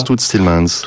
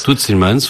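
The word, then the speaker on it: Toots Thielemans: Alors un personnage qui vous a influencé, je crois, c'est un Belge qui s'appelle Todd Stillmans. Alors je sais pas si je prononce bien son nom. – Todd Stillmans, on prononce Todd Stillmans. – Todd Stillmans, voilà.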